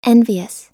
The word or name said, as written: envious